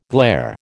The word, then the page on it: glair: American English